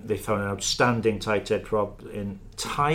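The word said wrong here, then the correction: Tadhg: is that how you say it? – I would go with Tag myself.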